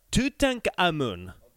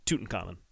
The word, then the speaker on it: Tutankhamun: One of the last singles she released, in fact, I think it may have been the last one she released, was a song called Tutankhamun, which is just the kind of more German pronunciation of Tutankhamun.